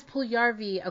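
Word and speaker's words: Puljujärvi: And I'm going to say his name wrong because I heard someone say, what's his name? He requested a trade. – Oh. – We can say. – Oh. Puljujarvi? – Yes, Puljujarvi. Okay.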